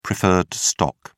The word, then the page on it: preferred stock: British English